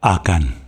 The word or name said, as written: Akan